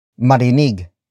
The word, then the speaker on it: marinig: Marinig, kaya, Nina, Michelle, at Melinda ang chismis marinig, kaya, Nina, Michelle, at Melinda ang chismis. The first word is marinig, marinig, marinig.